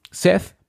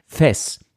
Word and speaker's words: Seth: Gerade Juliette Lewis, also ihre Sprecherin am Ende des Films, sagt ganz oft Thess. Das passiert teilweise wirklich, dass sie mit TH anfängt und mit S endet.